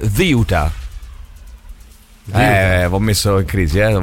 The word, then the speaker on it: Ceuta: In spagnolo della Spagna si dice Ziuta. – Ziuta. – Ziuta. Se poi lo volete pronunciare in spagnolo ispanoamericano, diciamo sì, così, o italianizzandolo, diventa Ceuta.